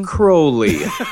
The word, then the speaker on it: Crowley: Crowley is how you pronounce it. Aleister Crowley.